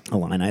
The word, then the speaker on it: Illini: Are those the Illini? – The Illini? – Illini, is that what it is? – Yeah.